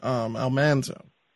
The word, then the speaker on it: Almanzo: Now, I like the fact, and I appreciate the fact that you use the correct pronunciation, Almanzo. It's something I'm trying to fall into the habit of. – For heaven's sakes, people, M-A-N is a man, not a mon.